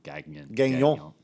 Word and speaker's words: Gagnon: Gagnon. It's French. Mignon. Guy, he, like the meat.